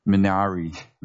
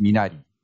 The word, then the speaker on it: Minari: And I guess if you pronounce it in English, it would be Minari, something like that. But in Korean, it's pronounced Minari.